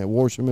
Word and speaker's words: Worsham: Worsham? I think it's how you say it. Yeah, Worsham if you got him.